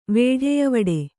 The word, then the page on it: ವೇಢೆಯವಡೆ: kannada